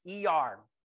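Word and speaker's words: Iyar: The second month on the Jewish calendar, counting from Nisan, is, let me make sure I say it right, E-R, even though it's spelled L-Y-A-R, it's pronounced E-R.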